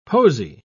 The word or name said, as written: posy